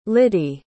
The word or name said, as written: lydie